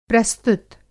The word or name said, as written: प्रस्तुत